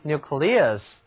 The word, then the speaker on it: nucleus: 另外一个有内外层次的物体，它的最内部就称为核心，这就是 nucleus， N-U-C-L-E-U-S nucleus。在这里字母组合 E-U 读音是 ear， nucleus 表示的就是核心、中心。它较多的出现在物理学中，因为物理上的粒子都是有其核心的，比如说原子核就是 atomic nucleus， atomic nucleus 原子核。